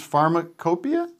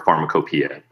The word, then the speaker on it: Pharmacopoeia: Let me just say, if people don't know Hamilton, your show, Hamilton's Pharmacopoeia, is that how you say it? – That's how you say it, or Pharmacopoeia, both are fine.